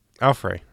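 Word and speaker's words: Alfre: And although it's not spoken aloud, the credits confirm Alfred Woodard is a woman named Miriam. Alfrey. I said Alfred. How do you pronounce her first name? – I assume it's Alfrey.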